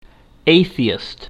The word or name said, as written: atheist